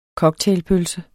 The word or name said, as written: cocktailpølse